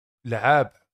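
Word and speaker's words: La'eeb: Das heißt, Laeb, oder warte, ich muss das mal rausschreiben, weil da wollte ich dich nämlich direkt fragen, wie das ausgesprochen wird. Schau mal bitte hier, unter Maskottchen. – Ja, gut. Laeb.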